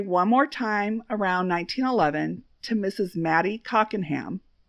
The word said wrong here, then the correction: Cockerham: Oh, I said that wrong. Mrs. Mattie Cockerham, who happened to live with him in 1910.